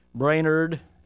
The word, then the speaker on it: Brainerd: Brainerd, emphasis on the nerd at the end.